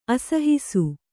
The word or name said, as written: ಅಸಹಿಸು